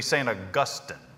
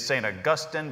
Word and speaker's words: St. Augustine: I sometimes slip off into St. Augustine just because I am a North Carolinian, and it feels good to romance your words just a little bit. But the proper way to say it would be St. Augustine. If you're going to read it in its original form, where the stress is, it is St. Augustine.